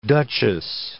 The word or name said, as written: duchess